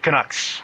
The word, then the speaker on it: Canucks: I'm going to totally mispronounce this. Canucks? Canucks? Is that right? – Canucks.